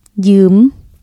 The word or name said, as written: ຢືມ